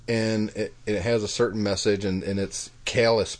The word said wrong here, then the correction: Cialis: pills, or how do you say that? Calius? Callus? – I don't know. Cialis.